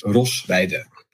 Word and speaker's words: Rosweide: But it's my name is, so I'm Dutch, right? So it's very hard to pronounce. I always tell my friends life's too short to learn any Dutch. You really shouldn't. But my name is Rosweide.